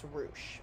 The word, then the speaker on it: Roush: It's spelled as Roush, but it's Roush. I heard...